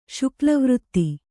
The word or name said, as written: ಶುಕ್ಲವೃತ್ತಿ